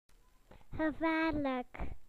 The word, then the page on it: dangerous: British English